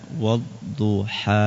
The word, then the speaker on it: والضحى: Yang sering kepanjangan itu adalah ketika membaca wadduha, padahal itu aslinya berapa harokat? 2.